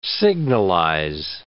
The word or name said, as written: signalize